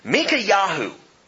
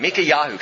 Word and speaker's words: Micaiahu: Now, when Micaiahu, and I know it doesn't look like that, but that's the pronunciation of this guy's name, Micaiahu. Kind of like Netanyahu, but put Mika instead of Netan.